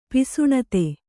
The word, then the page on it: ಪಿಸುಣತೆ: Kannada